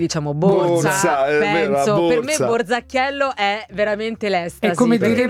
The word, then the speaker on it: Borzacchiello: Allora si scrive Borza come borsa con la Z, Borzacchiello. E non Bulzacchiello. – Borzacchiello, Borzacchiello.